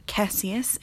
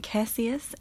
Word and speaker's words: Cassius: Beatrix, Bodhi, Cassius, and I have strong opinions about this being Cassius and not Cassius.